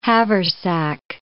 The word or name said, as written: haversack